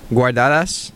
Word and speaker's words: Guardadas: Wait, how do you pronounce that? You. – Guardadas.